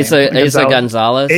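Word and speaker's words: Eiza González: Aza Gonzalez.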